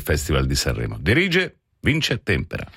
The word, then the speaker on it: Tempera: Siamo sicuri che si pronuncia Tempera, Sì. – Magari non che sia Tempera, perché non credo. – No, Tempera. – Tempera, come il Tempera Matita.